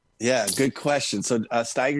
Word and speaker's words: Steiger: Am I pronouncing Steiger correctly? And go ahead. – Well, yes, you are.